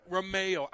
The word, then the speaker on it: Alfa Romeo: Did I say that right again? – Romeo.